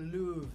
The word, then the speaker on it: Louvre: Yeah, it's a Louvre. Louvre. – The Louvre. – Yeah, it's spelled like Paris. Louvre. – Yes. – But it's Louvre.